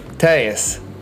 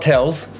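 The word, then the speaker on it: Telles: It's not Tells, and it's not Tellies.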